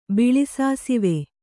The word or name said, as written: ಬಿಳಿಸಾಸುವೆ